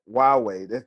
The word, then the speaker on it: Huawei: It's Huawei. It doesn't look like that, but that's how you say it.